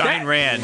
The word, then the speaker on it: Ayn Rand: How to correctly pronounce Ayn Rand's name. – Yeah. I didn't look it up. – It's Ayn Rand.